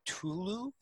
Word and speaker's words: Ktulu: Um, then wow, we get the the closer, which is the call of Ktulu.